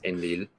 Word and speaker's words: Enlil: So this sign represents the syllable ein, this one represents the syllable lil, and so you read it as enlil, mm-hmm.